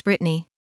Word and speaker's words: Bretagne: And Brittany, while the name looks like it's French, it's spelled B-R-E-T-A-G-N-E. It is actually pronounced Brittany.